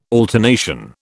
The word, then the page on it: alternation: British English